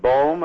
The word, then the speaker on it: Bohm: And he references the work of a physicist by the name of David Bohm, if I pronounce it correctly. – Very good, yes.